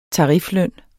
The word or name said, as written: tarifløn